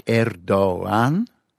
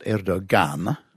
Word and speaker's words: Erdoğan: And this G with a little half circle, it isn't pronounced as a G at all. So he's not Erdoğan. – Mm-hmm. – What it does, the G does, it actually lengthens, awfully, it lengthens the preceding vowel.